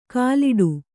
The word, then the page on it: ಕಾಲಿಡು: kāliḍu